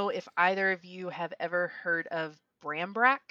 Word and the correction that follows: barmbrack: no. I think I'm saying that right. – No. – Uh, if anybody speaks, what is it, Celtic or Gaelic? Correct me. Barmbrack. It's barmbrack.